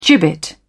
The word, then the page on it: gibbet: British English